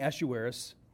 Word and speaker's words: Ahasuerus: As we learn about King Ahasuerus, that gets pronounced several different ways. Dean pronounced it one way. My wife pronounces it another way. I'm going to say Ahasuerus.